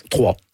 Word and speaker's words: Troyes: Vous commencez à jouer, bon, vous êtes né à Troyes, on dit Troyes? en France? Comment on prononce? – Trois.